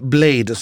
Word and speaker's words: Bladé: Precis, eller då Blade som det ska uttalas. Men det stavas alltså Blade och så är det E med två prickar.